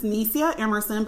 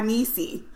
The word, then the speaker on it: Nycia Emerson: Okay, my name is Nycia Emerson, but I go by Nycia.